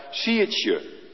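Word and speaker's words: siedzje: Daar staan een paar letters, daar staat siertje. Zaaien in het Fries. Zeg ik het goed? Helemaal goed, dankjewel.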